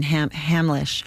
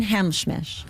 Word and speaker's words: Hamlisch: And then, but the earlier time she presented, in more of the 70s, late 70s, I think, she came out and she couldn't pronounce the name of the guy and it was Marvin Hamlisch. And she, the composer. – Yes. And she said, "And Marvin Hamschmish."